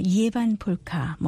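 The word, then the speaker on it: Ievan polkka: 이에반 폴카, 뭐 이에바의 폴카라고도 발음하기도 하는데요.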